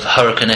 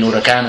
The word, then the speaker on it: Huracán: His mate came over, Henry May, and as I said, he kind of crosses the divide between Huracán and Huracán. – Interestingly, you just pronounced it the English way when you said the.